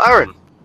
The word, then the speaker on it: Aaron: Aaron here. People say, how do you spell that? And you go A-A-R-O-N, and they kind of reply, oh, Aaron. No, it's Aaron.